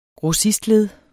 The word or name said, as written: grossistled